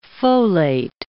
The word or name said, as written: folate